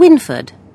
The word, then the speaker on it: Wynford: S-H-E-I-L-A. And I spell my second name with a Y. That's W-Y-N-F-O-R-D. – Can you spell it again, please? – Yes, Sheila. S-H-E-I-L-A. And Winford. W-Y... N-F-O-R-D.